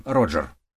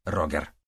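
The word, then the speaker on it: Roger: Они назвали его Роджер. Они сознательно выбрали имя, которое можно легко произносить даже на английском языке. И с годами английское произношение также стало популярнее, чем немецкий вариант «Рогер».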